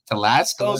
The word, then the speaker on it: Telesco: Telesco, is how you pronounce it? Yeah, okay.